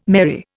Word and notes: merry: Indian

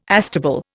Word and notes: astable: Indian

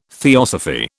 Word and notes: theosophy: British English

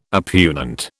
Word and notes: oppugnant: British English

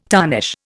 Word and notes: tarnish: Indian

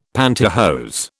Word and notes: pantihose: British English

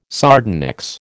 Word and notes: sardonyx: American English

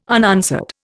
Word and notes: unanswered: Indian

